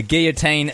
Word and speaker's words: guillotine: I'm a big fan of guillotine as a pronunciation. Guillotine. That's how we were taught as Kiwis. But probably the correct way is guillotine, right? Because is that the French? It's a French word.